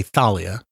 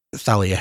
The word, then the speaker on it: Thalia: But the tree, I'm going to say Thalia, because there is a Greek letter theta. I assume they have a TH. – Good point. – So I'm going with the British pronunciation, Talia.